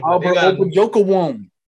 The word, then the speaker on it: Albert Okwuegbunam: Albert Okwuegbunam.